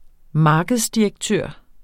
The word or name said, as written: markedsdirektør